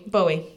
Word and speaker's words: Bowie: Bowie. Sorry. – Bowie is the correct pronunciation. – Okay, I'm going to say Bowie.